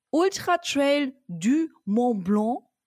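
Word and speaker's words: Ultra-Trail du Mont-Blanc: Und zwar warst du beim Ultra Trail du Mont Blanc. War das schön ausgesprochen? – Sehr gut ausgesprochen.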